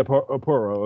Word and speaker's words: Porro: But I think probably the big rumor that's out there now is Pedro Parra, or is it Parra or Pora, is it pronounced? You guys know me and my pronunciation, but Porro, isn't it? – Yeah, Porro. Okay, there we go.